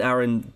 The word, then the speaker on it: Aaron: It is Aaron because it rhymes with Karen, and I think that in the stage musical they do like a joke about that.